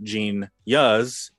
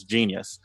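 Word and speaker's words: jeen-yuhs: For those who missed it, that's how it's pronounced. It is not Gene Yuz. Don't make yourself look like an idiot in front of your peers by saying that. It is Genius.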